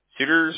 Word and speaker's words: Suter: And let's see, just for account purposes, Joseph, your first name, you spell that J-O-S-E-P-H, right? – Correct. – Perfect. All right. And what's your last name? Suter? – Suter, S-U-T-E-R.